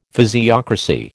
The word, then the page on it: physiocracy: American English